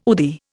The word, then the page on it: UDI: Indian